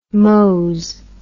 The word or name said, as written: mose